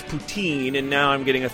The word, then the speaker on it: poutine: And second of all, Tim, it's pronounced poutine.